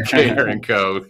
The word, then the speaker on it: Carencro: You are with Coastal Machine and Supply down in Carencro. Is that how you pronounce it? Carencro, I should know that by now. – You got to get that little Cajun in you there. – Carencro, Louisiana.